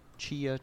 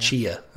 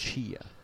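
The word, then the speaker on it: Tchia: Chaya. I still don't know how to say the name of that game. – Yeah. Chia, I think it is. – Chia. Okay.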